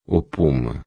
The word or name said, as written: puma